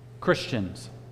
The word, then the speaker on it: Christian: We are Christians. We've talked about this before, that term Christian. And actually, you can pronounce it Christ-ian. We are Christ-ians. That little I-A-N at the end of it.